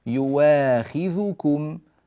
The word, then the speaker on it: يواخذكم: يعني أبدلها، أبدل الهمزة واو، فصار النطق يواخذكم، يواخذكم، هذه كلمة يواخذكم.